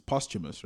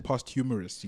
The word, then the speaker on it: posthumous: They just dropped his second, and Swesh taught me how to say this word properly. It's posthumous, right? – Hey man, we have to hit up Google, but I've always known it was posthumous. – I was actually saying posthumous.